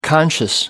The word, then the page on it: conscious: American English